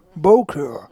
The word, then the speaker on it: Bowkr: et moi je prononce pas très bien les mots comme ça que je comprends pas, B-O-W-K-R. Peut-être. – Bowker. – Merci.